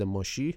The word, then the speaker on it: مشیح: شیر یهودا همینطور نماد مشیح یا منجی موعود یهودیان هم هست، حالا همون تقریبا تلفظ شبیه مسیح داره، میگم مسیح یا مشیح